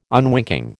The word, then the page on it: unwinking: American English